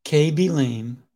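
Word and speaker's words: Khaby Lame: That's right. I said that correctly. KB Lame, number of followers, 80 million.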